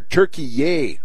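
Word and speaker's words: Türkiye: And now suddenly we're supposed to start mispronouncing the name of the country. It's like Kiev in Ukraine, Brian, because we had to start they told us to start mispronouncing that. And here they are with their Turkey-yay.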